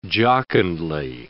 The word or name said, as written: jocundly